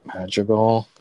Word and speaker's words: Madrigal: Madrigal.